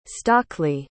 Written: stokley

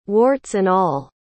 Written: warts-and-all

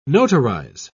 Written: notarize

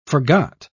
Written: forgot

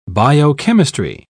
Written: biochemistry

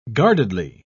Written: guardedly

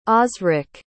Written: osrik

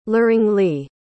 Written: luringly

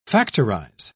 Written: factorize

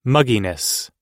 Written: mugginess

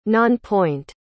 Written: nonpoint

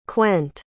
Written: quent

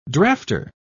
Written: drafter

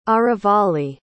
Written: Aravali

